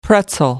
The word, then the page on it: pretzel: American English